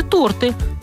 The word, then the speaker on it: торты: Вот такое ленивое неподвижное ударение в слове «торты» всегда на «о».